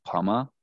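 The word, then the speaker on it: Pumma: So I think it's pronounced Puma, because it's P-U-M-M-A, but they're the Cougars, and I'm going to pretend that his name is Ty Puma.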